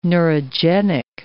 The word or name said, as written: neurogenic